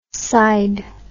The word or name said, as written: cide